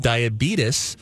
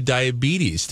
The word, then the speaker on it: diabetes: You went with diabetes as opposed to diabetes. Tell me about your choice. – He was correct in his pronunciation. – What? – Really? – You can actually say it both ways.